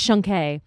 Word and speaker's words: Shunk: Also, just so you know, my last name is pronounced Shunke.